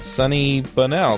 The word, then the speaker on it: Sunny Bonnell: This week, we have a special guest, and that's Sunny Bonnell.